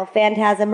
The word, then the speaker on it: Phantasm: Phantasm rhymes with orgasm.